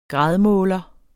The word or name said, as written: gradmåler